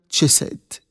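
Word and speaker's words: تشيسيد (חסד): إنها كلمة عظيمة باللغة الأصلية للعهد القديم، هي تلفظ حرفيا تشيسيد.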